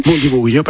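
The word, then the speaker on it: Bundibugyo: ¿qué diferencia esta cepa Bundi Vugio, que no sé si lo estoy pronunciando bien. – Bundi Vugio, perfecto.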